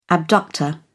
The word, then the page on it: abductor: British English